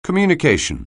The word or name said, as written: communication